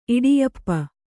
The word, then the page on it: ಇಡಿಯಪ್ಪ: kannada